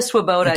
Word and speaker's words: Swoboda: Gina, am I pronouncing right? Svoboda. – Yes, Gina Swoboda is an incredible leader in election integrity.